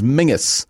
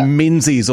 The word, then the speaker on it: Menzies: But the thing is that the Scottish pronunciation is Mingus, and it's like a marker to see if you like actually fit there or not, whether you say Menzies or Mingus.